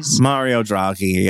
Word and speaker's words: Mario Draghi: Mario Draghi, yeah.